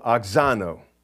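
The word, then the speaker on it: auxano: It's A-U-X-A-N-O. A-U-X-A-N-O, oxano.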